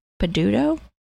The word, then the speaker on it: Peduto: Okay, I hope I'm not butchering the name, but Bill Peduto? Peduto? – Sounds right to me.